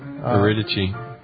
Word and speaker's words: Eurydice: and her name is I think you pronounce it Eurydice. – Eurydice. – Is that how you pronounce it? Wow, I couldn't have been more wrong. – Well, that's the Greek.